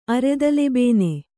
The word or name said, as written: ಅರೆದಲೆಬೇನೆ